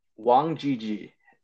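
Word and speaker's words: Wang Zhizhi: Wang Jiji. It's spelled like Wang ZZ.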